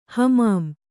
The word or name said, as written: ಹಮಾಂ